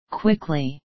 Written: quickly

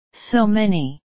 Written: so many

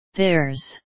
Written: theirs

